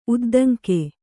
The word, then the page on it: ಉದ್ದಂಗಿ: Kannada